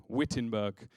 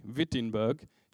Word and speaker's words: Wittenberg: Now, the best thing about the place Wittenberg is you spell it with a W, Wittenberg. But then when you say it with a V, Wittenberg, you just sound German straight away.